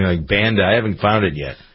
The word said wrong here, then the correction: Banza: Banza? Yeah, that's Banza. Awesome. Yeah, B-A-N-Z-A.